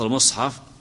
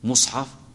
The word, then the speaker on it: مصحف: أي نطقها، لفظ المصحف أو ميم المصحف، هل يجوز فيها الضم مصحف؟ وهل يجوز الفتح المصحف والكسر؟ أي نعم، مر معنا أنها مثلثة، تقول هات المصحف وخذ المصحف واقرأ في المصحف، هو كتاب واحد لكن ميمه مثلثة.